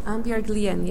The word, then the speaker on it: Annbjørg Lien: Yeah, and you were playing last night with Annbjørg Lien, is that, have I pronounced that correctly? – Annbjørg Lien, yeah,